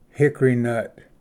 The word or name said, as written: hickory nut